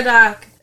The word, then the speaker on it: Murdaugh: Murdoch. It's spelled I was saying Murdoch, like Madoff. – Yeah, I know. – But it's spelled D-A-U-G-H, like the Murdoch, the Doff part.